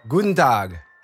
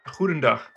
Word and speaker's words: Goedendag: Guten Tag. – That's close, yeah. – Correct my terrible Dutch. What's the proper pronunciation? – Goedendag.